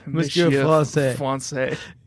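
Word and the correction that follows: Monsieur François: Monsieur Francois.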